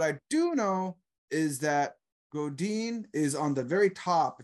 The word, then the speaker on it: Godin: Now, did I say that right? Godin, or is it Godin? – Godin. – Okay, good. I said it right.